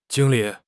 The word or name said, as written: jing1 li3